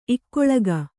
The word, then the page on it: ಇಕ್ಕುಳಿಕೆ: kannada